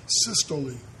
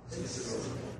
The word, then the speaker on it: systole: Systole. – Systole.